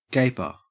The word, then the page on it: gaper: Australian English